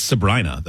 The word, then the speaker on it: Sabrina: I think it's Sabrina, though, because I remember reading somebody said that the pop singer rhymes with vagina.